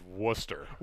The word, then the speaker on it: Worcester: So I believe the correct terminology is Worcester.